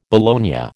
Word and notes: bologna: American English

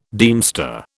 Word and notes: deemster: British English

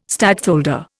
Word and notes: stadtholder: Indian

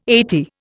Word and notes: eighty: Indian

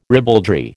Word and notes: ribaldry: American English

